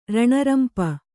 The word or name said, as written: ರಾಣಾರಂಪು